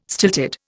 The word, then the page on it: stilted: Indian